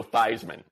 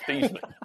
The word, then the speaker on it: Theismann: And literally change the pronunciation of the man's name to Joe Theismann instead of Theismann.